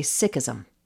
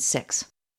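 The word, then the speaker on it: Sikh: So rather than Sikhism or Sikhs, you're going to hear him say Sikhism and Sikhs.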